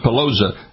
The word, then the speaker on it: Peloza: Dr. John Peloza, P-E-L-O-Z-A. Did I say that right, Doc? Peloza, right, Doc? – Yes. – Yeah, good.